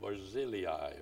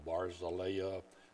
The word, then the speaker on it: Barzillai: But beginning in verse 31, we read about a man by the name of Barzillai, or Barzillai, depending on where you're from, how you want to pronounce this.